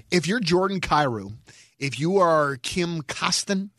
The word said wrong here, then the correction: Klim Kostin: isn't that Kostin? – Klim Kostin. – Klim Kostin, yeah. Don't get his name wrong.